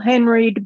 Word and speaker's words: Henreid: Again, it's We Love Paul, H-E-N-R-E-I-D. Follow her. – I think both. – He pronounced it Henreid, didn't he? – It's Henreid. He's doing fine.